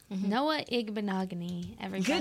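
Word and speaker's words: Igbinoghene: So I said, Noah Igbinogany. And then I sounded it out for her.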